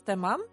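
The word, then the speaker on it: тәмам: Нет, ну, не тэмэм, тэмам. – Ты будто бы два А произносишь. Будто первое не мягко, будто говоришь тамам.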